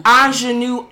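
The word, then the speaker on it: Aunjanue: Who was the Anjanae? Is that how you say her name? – Anjanue Ellis.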